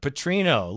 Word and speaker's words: Petrino: Bobby Petrino. Okay. I said it right that time. Petrino, Louisville head coach, first choice of the year ago.